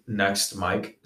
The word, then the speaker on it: NXTMIKE: I go under the name Next Mike. So it's spelled NXTMIKE.